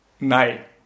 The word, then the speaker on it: Ney: Er heißt Nei. Nei wird er ausgesprochen, man schreibt ihn aber N-E-Y. Okay. – Das heißt, jeder, der mich kennt, kann sich jetzt auch denken, warum ich den vielleicht manchmal als Ney aussprechen werde.